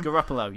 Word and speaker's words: Garoppolo: Garoppolo, yeah.